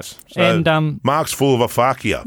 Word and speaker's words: aphakia: It's called a fakia.